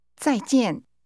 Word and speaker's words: 再见: That's pronounced with two falling tones. Say goodbye. – 再见。